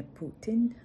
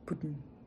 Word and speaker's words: Putin: Це е, буде не Путін, а Путін. Путін.